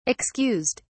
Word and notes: excused: British English

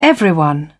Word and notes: everyone: British English

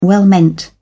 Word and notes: well meant: British English

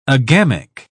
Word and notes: agamic: British English

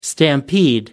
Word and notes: stampede: American English

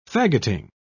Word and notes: faggoting: British English

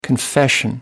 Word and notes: confession: American English

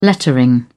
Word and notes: lettering: British English

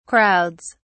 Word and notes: crowds: British English

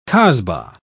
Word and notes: casbah: British English

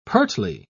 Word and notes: pertly: British English

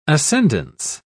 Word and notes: ascendence: British English

